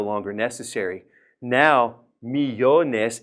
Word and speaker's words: millones: If it's three syllables, we stress the middle syllable. So now we don't need the accent. Millones. We're already stressing the middle.